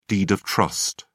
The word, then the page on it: deed of trust: British English